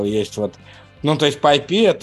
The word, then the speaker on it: PyPI: Мне нравится второй пункт в наших темах, как раз про безопасность, о том, что в репозитории PyPI, или как он правильно читается, питоновского... – PyPI.